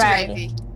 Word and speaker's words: Ravi: Ravi. Is it right? – It's Ravi. – Ravi, like Raven. Ravi.